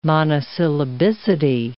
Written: monosyllabicity

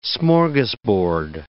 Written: smorgasbord

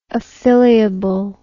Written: affiliable

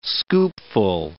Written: scoopful

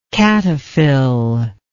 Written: cataphyllary

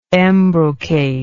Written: embrocate

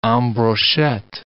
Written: en brochette

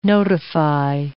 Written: notify

